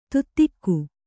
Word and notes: ತುತ್ತಿಕ್ಕು: kannada

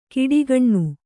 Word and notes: ಕಿಡಿಗಣ್ಣು: Kannada; kiḍigaṇṇu

